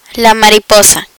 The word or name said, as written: mariposa